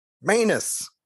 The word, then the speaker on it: Maness: Maybe you could say it should have been stopped, Maness. Manus. It's yeah, again, it's not, right? Not phonetically anyway.